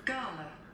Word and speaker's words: gala: Do the UK pronunciation. – This is how they say it in British English. – Gala. – Oh, so if you say gala, you're actually a little more sophisticated.